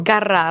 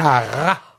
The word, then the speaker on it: Carrà: Carrà, Raffaele Carrà. – Carrà. Carrà. Io faccio la R moche. À la Torinese, là où tu es toi d'ailleurs.